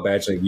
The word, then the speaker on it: Badgley: And I hope I said his name right, because Tyler's not here to correct me. – I believe you said his name right. I did not. So normally I say Marvin, the Pistons player. I was like, fuck. It is Michael Badgley. You said it correctly.